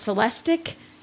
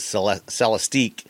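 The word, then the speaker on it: Celestiq: But you've already said it's Lyric. You've already said it's Celestic. So if you do the ick is how you pronounce it ending, it's going to be the Escalade ick.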